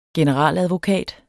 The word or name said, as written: generaladvokat